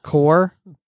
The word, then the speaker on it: Corps: Corpse? – Yeah. – Core? – Corpse. – Core. – I like to use the word corpse. – But that's not how it's pronounced. It's pronounced core.